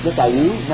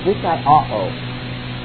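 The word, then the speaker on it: dikaiōō (δικαιόω): You got those two O's there, and that throws you off. You want to say dikaiōō? No, dikaiōō. Separate the syllables. Omicron followed by omega. Short O followed by long O. Dikaiōō is how it's pronounced.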